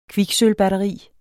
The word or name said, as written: kviksølvbatteri